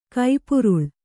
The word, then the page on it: ಕಪ್ಪುರಾಳ: kappurāḷa